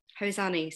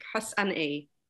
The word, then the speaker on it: Hosani: Hosani, so as you say, Hosani. It's phonetic, Hosani.